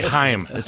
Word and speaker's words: Chaim: I'm glad you pronounced that right, Chaim Bloom there. – Well, we talked about that, and people just say Chaim. It's Chaim Bloom, yes.